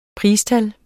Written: PRISTAL

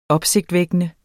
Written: opsigtsvækkende

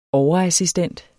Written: overassistent

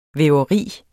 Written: væveri

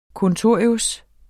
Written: kontorius

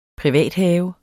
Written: privathave

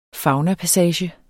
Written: faunapassage

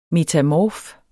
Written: METAMORF

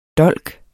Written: DOLKENES

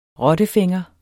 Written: rottefænger

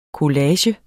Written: collage

